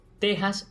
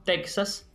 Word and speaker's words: Texas: Como escuchó, dije Texas y no Texas, pues es Texas la pronunciación adecuada de este topónimo que da nombre a ese territorio estadounidense. Teniendo en cuenta el apartado de la ortografía académica, referido a los distintos valores de la X en cuanto a pronunciación, esta obra nos recuerda que en este caso la X conserva su antiguo valor como representante del fonema J, como mismo ocurre con otros topónimos, como México, Oaxaca.